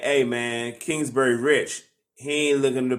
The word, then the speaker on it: Kingsbury: Why are sports news outlets saying that Cliff Kingsbury, that's his name, Kingsbury. Am I saying his name right? – Kingsbury.